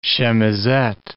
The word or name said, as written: chemisette